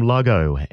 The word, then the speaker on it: Lahgo: Quickly followed on from Lago and What I Need. I think I've pronounced that correct. L-A-H-G-O.